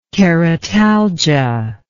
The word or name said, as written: keratalgia